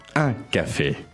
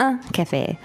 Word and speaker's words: un café: Un café. – So let's work on the pronunciation of that. – Uh-huh. Un. – Un. – Un. – Mm-hmm. Un café.